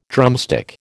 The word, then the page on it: drumstick: American English